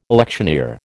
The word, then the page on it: electioneer: American English